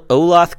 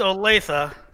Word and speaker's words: Olathe: Will Olathe, Kansas. Manville. – First of all, no, man. It's pronounced Olathe.